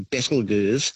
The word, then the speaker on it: Betelgeuse: It's a fun way of pronouncing the name, actually, Betelgeuse. But the correct pronunciation is said to be Betelgeuse, which comes from an old Arabic name, meaning the armpit of the giant.